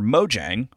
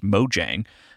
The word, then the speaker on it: Mojang: Minecraft developer Mojang, which I learned is pronounced Mojang in Sweden, where the studio is based, and then in America, it is pronounced Mojang.